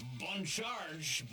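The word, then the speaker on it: Bon Charge: This half hour, we thank the fine people at Bun Charge. Nailing that slightly French pronunciation.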